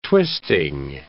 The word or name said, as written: twisting